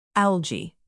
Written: algae

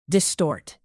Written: distort

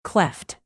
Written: cleft

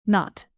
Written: not

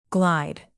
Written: glide

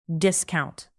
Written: discount

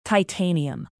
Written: titanium

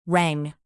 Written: rang

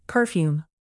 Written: perfume

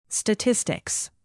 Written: statistics

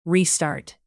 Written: restart

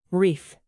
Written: wreath

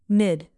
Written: mid

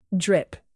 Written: drip

